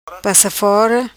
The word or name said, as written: Passa fora